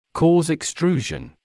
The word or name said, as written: cause extrusion